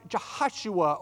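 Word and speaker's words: Yehoshua: It's actually pronounced Jehoshua, or almost like Joshua.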